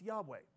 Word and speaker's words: Yahweh: Most scholars now believe that it should be pronounced Yahweh.